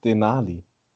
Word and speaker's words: Denali: Das hast du jetzt einfach nur falsch ausgesprochen, das alles. Das ist weder Essen noch Berg. Nee, wie heißt es? Denali.